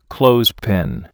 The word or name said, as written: clothespin